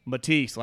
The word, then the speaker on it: Matisse: Matisse.